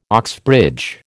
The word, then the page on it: Oxbridge: American English